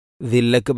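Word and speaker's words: ذلة: തർഹഖുഹും സില്ല, ദില്ലക്ക് പകരം പലപ്പോഴും സില്ല. ഇതൊക്കെ ശ്രദ്ധിക്കണം. തർഹഖുഹും ദില്ല.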